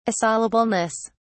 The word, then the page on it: assailableness: British English